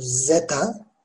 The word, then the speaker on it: zeta: Okunuşu zeta. Aslında yine peltek söyleniyor bu harf. Zeta, zeta diye.